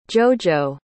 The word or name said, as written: jojo